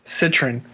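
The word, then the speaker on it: Cytryn: Pretend the Y's are short I's. So it's just Citrin. – Say it for me. I want an example. – Citrin.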